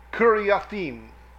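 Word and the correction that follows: Kiriathaim: Kiriathayim, pardon me, 7741.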